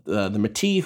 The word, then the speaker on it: Métis: Which is a really, really interesting, you know, history of the Métis, who are a, I believe I'm pronouncing that right. I believe the S is silent there.